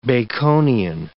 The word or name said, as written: Baconian